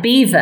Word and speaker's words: Belvoir: Now, Scone is actually spelt, it's rather like Beaver, our home, which is spelt to the readers, they would say Belvoir.